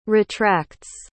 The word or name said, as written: retracts